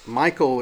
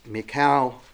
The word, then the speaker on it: Michal: His wife's name looks like it's Michael. It's probably pronounced Michal.